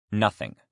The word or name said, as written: nothing